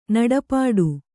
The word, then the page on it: ನಡೆಪಾಡು: kannada; naḍe pāḍu